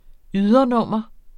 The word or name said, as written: ydernummer